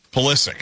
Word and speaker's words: Pulisic: All good so far for the United States, even today without Pusilic. Is that how you pronounce the name? – Pulisic. – Pulisic. Yeah, I keep from mispronouncing it.